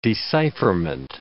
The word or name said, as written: decipherment